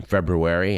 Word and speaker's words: February: And yes, there are two R's in February, and both are pronounced.